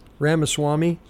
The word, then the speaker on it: Ramaswamy: Musk, I can't even pronounce his name. Vivisramim. And Ramaswamy, sorry, Vivek messed me up. Ramaswamy.